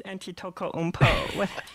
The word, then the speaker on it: Antetokounmpo: Got a nice Antetokounmpo. – In case you were wondering, that is the pronunciation.